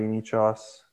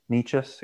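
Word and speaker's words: Nečas: I think Marty Necas is going to get better. Necas, excuse me. I think I said that right. – Yeah, you said it right.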